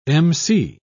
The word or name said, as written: emcee